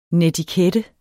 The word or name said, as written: netikette